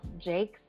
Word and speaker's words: Jakes: But quick note, my last name is pronounced Jakes. That is the southern way to say it. – Really? – Jakes.